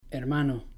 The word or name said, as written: hermano